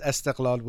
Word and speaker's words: Esteghlal: and he che- he cheers for Persepolis versus Esteghlal, which wears blue. Did I say that right, Amir John? Yeah.